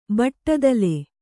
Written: ಬಟ್ಟದಲೆ

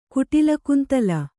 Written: ಕುಟಿಲಕುಂತಲ

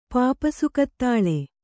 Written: ಪಾಪಸುಕತ್ತಾಳೆ

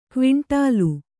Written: ಕ್ವಿಂಟಾಲು

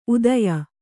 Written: ಉದಯ

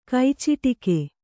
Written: ಕೈಚಿಟುಕು

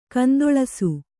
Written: ಗೊಂದಲಿಸು

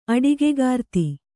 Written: ಅಡಿಗೆಗಾರ್ತಿ

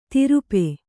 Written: ತಿರುಪೆ

